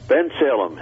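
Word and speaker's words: Bensalem: Now, next we have calling from, how do you pronounce that town? Ben Salem? – Ben Salem.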